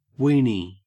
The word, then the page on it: weeny: Australian English